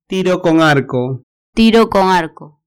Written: tiro con arco

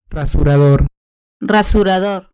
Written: rasurador

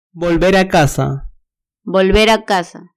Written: volver a casa

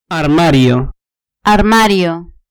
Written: armario

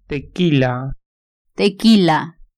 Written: tequila

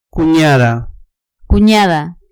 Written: cuniada

